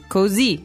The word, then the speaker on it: così: Remember, the accent is on the last vowel of that, so not così. – Not cozy, it's così. It's così carino.